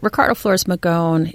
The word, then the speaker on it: Magón: Sure, Ricardo Flores Magón.